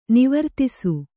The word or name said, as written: ನಿವರ್ತಿಸು